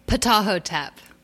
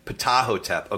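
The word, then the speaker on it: Ptahhotep: Remind us, not only, you already mentioned, I think, do you say Teotep, the teachings of Teotep? – Ptahotep. – Ptahotep. Okay. I was doing the silent P.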